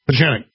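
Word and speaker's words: Pieczenik: Pajenik. – It's a hard C, Pajenik.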